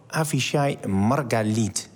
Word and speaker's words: Avishai Margalit: Je hebt je laten inspireren door de Israëlische filosoof Avishai Margalit. Zeg ik het goed? – Je zegt het goed.